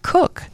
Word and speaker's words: Koch: Oh, she pronounces it Cook. It's K-O-C-H, and she's pronounced it Cook.